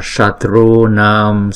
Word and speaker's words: Shatru Nam: Hana Hana Sarva Shatru Nam Swaha. Chữ S này ến cái lưỡi lên. Sha Tru Nam. Tru Nam. Chữ này đọc là Nam chứ không phải là Nam.